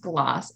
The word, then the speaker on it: Glas: So glass, it's pronounced gloss actually, which sounds a little snooty, but I swear it's not. Gloss is an Irish word. It means green.